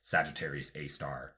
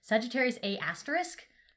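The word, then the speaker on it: Sagittarius A*: I'm glad you figured out how to pronounce it because I kept seeing it like Sagittarius A asterisk. That's a mouthful.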